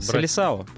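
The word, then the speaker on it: Seleção: Сборную Бразилию называют Селасио. Это избранные. Вот по... – Селисао, правда. Селисао? Селисао. Хорошо, Селисао. Я просто вот э, смотрю мне вот читается Селасио.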